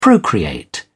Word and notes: procreate: British English

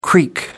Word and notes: creek: American English

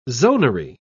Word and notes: zonary: British English

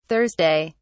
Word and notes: thurs: British English

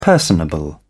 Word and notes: personable: British English

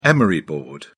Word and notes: emery board: British English

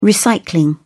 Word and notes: recycling: British English

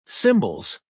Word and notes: cymbals: American English

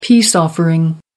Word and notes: peace offering: American English

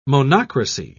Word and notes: monocracy: British English

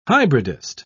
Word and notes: hybridist: British English

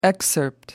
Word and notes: excerpt: American English

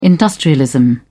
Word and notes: industrialism: British English

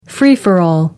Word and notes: free for all: American English

